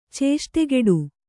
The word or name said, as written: ಚೇಷ್ಟೆಗೆಡು